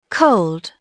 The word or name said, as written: cold